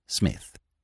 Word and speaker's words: Psmith: The name is Smith. P. Smith. The P is silent. – P. Smith? – Smith.